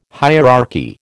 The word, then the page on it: hierarchy: American English